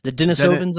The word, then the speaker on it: Denisovans: Are these the Denovians? Is that how you pronounce that? – The Denisovans, I believe.